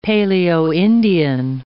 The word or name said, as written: Paleo-Indian